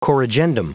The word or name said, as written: corrigendum